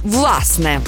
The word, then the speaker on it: власне: Наголос на першому складі «власне».